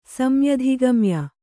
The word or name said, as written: ಸಮ್ಯಧಿಗಮ್ಯ